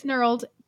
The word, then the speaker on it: knurled: Is that knurled or gnarled? I don't even know if that's a word. – It's knurled. K-N-U-R-L-E-D. Ugh. Knurled. I like to pronounce it with the K.